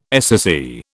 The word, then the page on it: SSE: British English